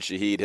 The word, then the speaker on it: Shaheed: The young man out of Weber State, has had a phenomenal season, and his name rhymes with speed. The Browns are going to have to be aware of that. Rashid Shaheed has been phenomenal.